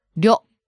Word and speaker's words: 料: You mean the double R's and plus that R-Y-O at the beginning? – Yeah, especially R-Y-O at the beginning. 료. – Yeah, that is a difficult concept to grasp, right? I mean, if you're reading the Romaji version of these words, like 料理, you might get the wrong impression on how to pronounce these words.